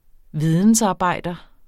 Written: vidensarbejder

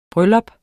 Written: bryllup

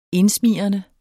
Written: indsmigrende

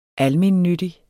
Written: almennyttig

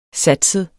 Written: satset

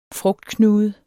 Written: frugtknude

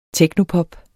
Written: technopop

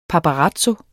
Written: paparazzo

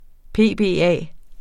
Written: PBA

